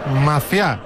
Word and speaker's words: Maciá: Maciá, se llama Carlos Maciá. – Tiene acento en esa A, ¿no? – Sí, tiene acento en la A.